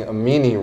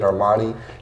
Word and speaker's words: Amini: Which, by the way, side note, I was pronouncing Amini wrong. And so I was telling all my friends, like, yeah, I know the person that designs and created Armani.